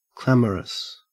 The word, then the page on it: clamorous: Australian English